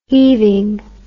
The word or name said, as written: heaving